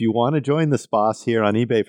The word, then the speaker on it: Spaß: If you're saying it correctly, it's Spaß.